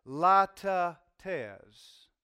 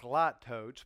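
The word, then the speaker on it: litotes: Now, Martin Lloyd-Jones says that that is a latte tes. I know, it's spelled like lattotes, but that's not how you pronounce it.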